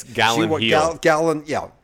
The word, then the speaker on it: Gyllenhaal: I had never seen this film with a very young Jake Gyllenhaal. – Yeah. – Am I saying his name right? How do you spell his last name? – It's Gyllenhaal. – A very young Jake.